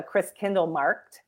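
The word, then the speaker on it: Christkindlmarkt: So it's called a Chris Kindle Markt. It's a German word, right? It's Christmas Market, basically, in German. And this is our fourth year doing the Chris Kindle Markt in Sister Bay.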